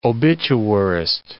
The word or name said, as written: obituarist